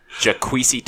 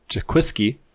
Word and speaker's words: Jaquiski: Jaquisi Tartt. – Jaquiski? – Nope. That second K is silent. There's only one K.